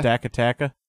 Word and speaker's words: Stakataka: And then Irene saw Stakataka, I think it is. Stakataka. – Stakataka.